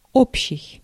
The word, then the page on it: общий: /ˈopɕɪj/